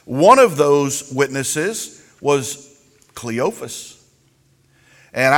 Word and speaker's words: Cleophas: And this is Mary, the sister, the wife of Cleophas. And some people pronounce it Cleopas.